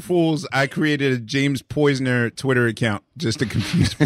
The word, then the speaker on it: Poyser: I said Poyser, okay? – No, we call him Poyser. – Say his name, Mariah. Say his name. – I'm just saying, I said James Poyser because everybody else says Poisner.